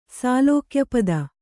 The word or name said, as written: ಸಾಲೋಕ್ಯಪದ